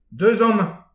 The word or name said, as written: deus aman